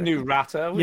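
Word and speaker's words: Naruto: Is it Nurato? – Yes.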